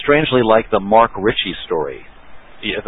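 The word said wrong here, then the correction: Marc Rich: Mark Rich.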